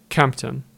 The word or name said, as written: Kemptom